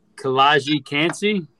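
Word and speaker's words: Kalijah Kancey: I'm like, I know he's not here, but then this next pick, I can't even say his name. Kalaji Kansi? – Yes. Kalaji. Pittsburgh D lineman.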